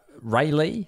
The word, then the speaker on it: Raleigh: How much sweet cash would I need to donate to the troops in order to get my merch here in Raleigh, North Carolina? – Raleigh. – This is quite a specific one. Is that how you spell it? Or say it, rather. – Yeah. Raleigh, North Carolina.